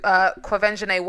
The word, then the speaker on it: Quvenzhané: It was the first black Annie with Quvenzhané Wallace. – Is that how you say her name? – Yeah.